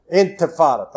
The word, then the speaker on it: Intifada: In the early 2000s, there was a Infantana, or something like that. I can't pronounce. – Intifada.